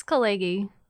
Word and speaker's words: Khaleghi: Is that how you say your last name? – It's Khaleghi. – Khaleghi? – Yes. – Like Caligula? – Like Khaleghi, but Khaleghi.